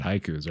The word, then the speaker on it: Haikyu: Now that's not haiku, like the 575 syllable poem, which I know all of our listeners know about haikus, right? Yeah. But it's actually haiku with like kind of an elongated U.